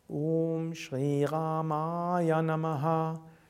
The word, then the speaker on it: Om Shri Ramaya Namaha: Om Shri Ramaya Namaha. Das Om, O ist lang, Shri, das I ist lang, Ra ist lang, also nicht Ramaya, sondern Rama, Ma ist wieder lang und das Ja ist kurz.